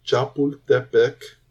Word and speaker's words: Chapultepec: In the film, you're taken to the Chapultepec Castle, which was a sacred place. I'm going to get murdered for that, but it's fine. For Aztecs. – It's good. You said it right.